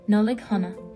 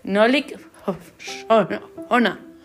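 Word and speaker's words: Nollaig Shona: En irlandés es Nollig Hona. – Eso sí era. Nolik Hona. O Sona, escrito Sona.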